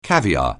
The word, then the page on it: caviar(e): anglais